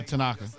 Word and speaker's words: Tanaka: Tanaka, how you say his name? – Yeah, Tanaka.